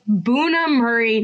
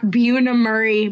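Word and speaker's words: Bunim/Murray: It's Buna Murray, not Buna Murray.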